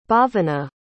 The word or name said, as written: bhavana